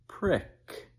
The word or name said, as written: prick